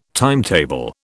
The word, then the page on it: timetable: British English